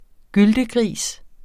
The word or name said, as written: gyltegris